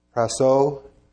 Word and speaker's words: prasso: It's spelled P-R-A-S-S-O with a line over the O. Which, what's that mean? You emphasize it? Praso, to practice, perform repeatedly or habitually, to execute, accomplish, differs from performing a single act.